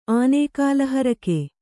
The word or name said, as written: ಆನೇಕಾಲಹರಕೆ